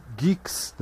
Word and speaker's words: Guix: O próprio Gix, né? que é assim que se pronuncia, mas escreve-se GUIX, é,